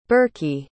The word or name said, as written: birky